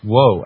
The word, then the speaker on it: Gou: And the owner of this company, a man named Terry Gou, or it's Guo, actually, I believe is the way they pronounce it, Chairman Terry Guo.